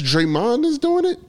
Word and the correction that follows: Draymond: Why did I say his name like that? Draymond. – Draymond.